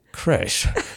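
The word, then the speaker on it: crèche: So messy creche. Did I get that right? – Yes, absolutely. Yes.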